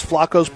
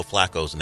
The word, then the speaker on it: Flacco: He's been this way forever. It's always been Flacco. – Flacco? Who calls him Flacco besides you? – Well, how do you say it? – Joe Flacco. – Did I say Flacco? – You've been saying Flacco for years.